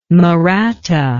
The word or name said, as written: Mahratta